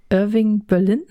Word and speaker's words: Irving Berlin: Irving Berlin, korrigier mich, spricht man das so aus? – Der, würde ich jetzt so sagen, ja.